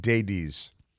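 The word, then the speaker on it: Daibes: I think Dades is how it's pronounced. It's like Davies, but with a D and a D.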